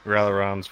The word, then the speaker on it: Ralliron: Ralliron's fine. – Ralliron. – Okay. I've gotten railroad, rail iron. you fucking name it. – Okay. All right. – Iron also works. Rally.